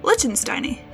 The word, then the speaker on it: Lichtensteini: Lichtensteini, yeah. By the way, this is the Lichtenstein is seahorse. Yeah, that's how you say it.